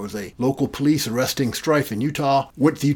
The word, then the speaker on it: Strieff: So that's Strieff, and that's how I found out how to pronounce his name.